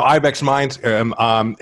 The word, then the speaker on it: Ibexmind: I don't know if I'm pronouncing Ibexmind right, but it's Ibex. Okay, it's my Dutch accent. So Ibexmind,